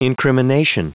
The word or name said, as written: incrimination